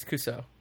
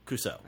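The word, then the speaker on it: CUUSOO: Yeah, so the LEGO has a site called Cuso, or C-U-U-S-O-O. I don't know how to pronounce that. – It's Cuso. – Cuso.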